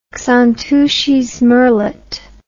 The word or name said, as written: xantus's murrelet